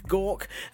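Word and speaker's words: Gauke: Back in the UK, Justice Secretary David, pronounce it like you're being sick, Gawk,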